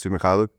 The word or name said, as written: Svi Mykhailiuk